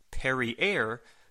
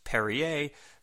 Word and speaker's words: Perrier: They're both sitting at a table, and Mark tells the waitress to bring them some Perrier, to which Chyna corrects him and says it's pronounced Perrier, so Chyna amusingly tells the waitress to bring some of that as well.